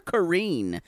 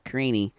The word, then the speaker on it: Karine: who calls her Karine instead of Karini. Who, so I guess that's her name. – Well, the Portuguese, it would be kind of a silent E, Karine. Karine.